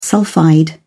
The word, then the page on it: sulphide: British English